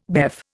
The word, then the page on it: BEF: Indian